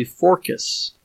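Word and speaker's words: Phorcys: I said, but the original Greek pronunciation was probably Forcus. And she said, Forcus? Why? And I said, well, because, you know, the original Greek, the C's were almost always hard K's.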